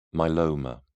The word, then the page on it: myeloma: British English